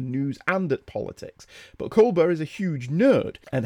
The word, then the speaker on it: Colbert: First, I wish to thank Michael Bailey, who emailed me in following last issue, telling me about Stephen Colbert, which he pronounces Colbert.